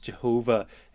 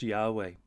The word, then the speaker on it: Yahweh: So if you don't remember or haven't realized that, when it's capital L-O-R-D, that means this is the personal name of God, the covenant name Yahweh. Traditionally pronounced Jehovah in English, but most reckon it should be pronounced Yahweh.